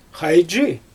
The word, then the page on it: xajdʒi: Xyzyl